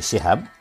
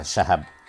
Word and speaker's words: Syihab: Aba Abdurrahman memilih Syihab, meskipun di Indonesia lebih populer Syahab. Aba bilang, kita pilih pengucapannya yang benar, walaupun tidak populer, daripada memilih yang populer tapi tidak tepat, kata Quraisy.